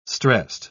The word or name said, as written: stressed